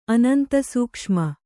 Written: ಅನಂತಸೂಕ್ಷ್ಮ

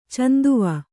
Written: ಚಂದವ

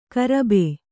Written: ಗರ್ಭ

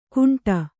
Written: ಘುಂಟ

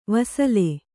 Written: ವಸಲೆ

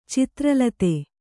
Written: ಚಿತ್ರಲತೆ

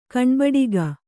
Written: ಕಣ್ಬಡಿಕ

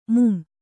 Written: ಮೂಮೆ

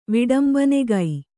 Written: ವಿಡಂಬನೆಗೈ

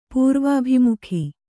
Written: ಪೂರ್ವಾಭಿಮುಖ

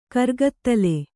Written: ಕರ್ಗತ್ತಲೆ

